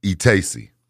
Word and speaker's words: Itesi: E-Tacy. Yeah. – How you spell that? – I-T-E-S-I.